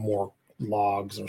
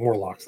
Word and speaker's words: Morlocks: I think I called them Morlogs or something. Morlocks, that's right. Morlocks, yeah. Sorry, I got the name wrong.